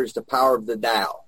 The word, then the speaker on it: Tao: In other words, and the Tao, when you hear that, it's T-A-O, but it's pronounced D-A-O, Tao, is about, is the same as your higher power.